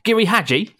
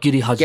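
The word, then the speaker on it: Giri/Haji: my pronunciation sounds awful. Giri Haji. It's more like Giri Haji.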